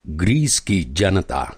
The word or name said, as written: ग्रीस